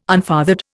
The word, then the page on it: unfathered: Indian